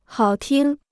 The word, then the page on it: 好听: hǎo tīng